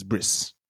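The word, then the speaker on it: bris: It's the Yiddish pronunciation is Briss. Briss.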